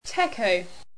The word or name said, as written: techo